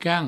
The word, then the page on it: quant: provençal